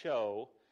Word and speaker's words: shew: Verse 26 says, as often as you eat this bread and drink this cup, you do shew, or the proper way to pronounce that is actually show, so you could put an O there if you're looking in the King James, the Lord's death until he come.